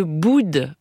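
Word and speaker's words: Boudes: Alors il m'est impossible de ne pas vous parler de Boude pendant cette semaine consacrée au Puy-de-Dôme. Boude est un village près d'Issoire qui s'écrit avec un S à la fin. C'est donc toi qui Boude et pas moi, et dont les habitants sont les Boudigans et les Boudiganes.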